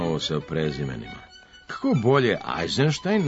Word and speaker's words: Eisenstein: Međutim, malo se tako uobličenih prezimena ustalilo, pa je primenjen objedinjeni obrazac sa izvornim izgovorom, bilo da prezimena dolaze iz Rusije ili sa zapada. Zato izgovaramo Rubinstein, Bernstein, Bronstein, pa i Eisenstein.